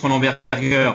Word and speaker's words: Berger: On, on prononce Berger parce que c'est le nom du fondateur qui était un Allemand, mais on peut le franciser, ça, convient aussi.